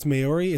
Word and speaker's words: Maori: I think it's Maori. It's M-A-O. – Maori. It's pronounced Maori. – You don't know that. – I do. M-A-O-R-I.